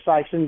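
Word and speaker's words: Sikeston: So what's the appropriate way to pronounce Sykeston then? – Well, Sykeston. Sykeston, does it? – Sykeston. – Yeah, kind of soften that I.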